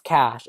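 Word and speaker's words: cache: Like, and then I was like, if cookies is pronounced cookies, why is cake pronounce cash? It should be cake.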